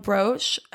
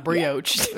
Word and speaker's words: brooch: There was a gemstone brooch. How do you say that? – Brooch. – Brooch. Okay. Right? Yeah. – It's not brioche.